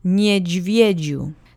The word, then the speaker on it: niedźwiedziu: Last word is niedźwiedziu. The penultimate syllable usually gets the accent, and it's no exception here.